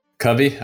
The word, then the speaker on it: Covey: I might have butchered that pronunciation a little Covey. – Bit. Covey. All right. I was right.